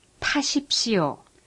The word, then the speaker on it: 타십시오: The T with an apostrophe is pronounced like the T in take, but with a strong puff of air. For example, 타십시오.